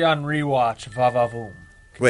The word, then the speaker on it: Henry: Oh, Henry! Like French Henry.